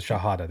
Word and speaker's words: Shahada: The Declaration of Faith, the Shahada. – The Shahada, there you go.